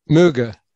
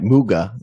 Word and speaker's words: Muegge: So let's get into your investigations of Kai Muegge. Is that how you say his name? – Muegge. – Right. Muegge, the German, correct?